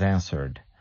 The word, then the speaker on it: answer: Yes, many learners of English as a second language, they have a problem with this verse, this verb to answer, because you have the W there, but the W is mute.